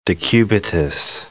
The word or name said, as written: decubitus ulcer